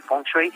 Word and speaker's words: feng shui: Así es, muchísimas gracias por la invitación, Kuailo, y la pronunciación que nosotros tenemos para el feng shui es así.